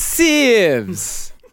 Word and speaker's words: sieves: I believe it's sevs. – It's sieves.